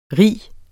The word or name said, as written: ri